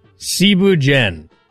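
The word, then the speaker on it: thieboudienne: First off, Cebu Jen. It's spelt like Thibu Dien.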